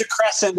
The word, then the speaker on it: DeCrescent: Sandy DeCrescent. Did I say that right? DeCrescent. Held on LinkedIn. – I think it's DeCrescent, but I like DeCroissant a lot.